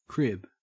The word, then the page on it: crib: Australian English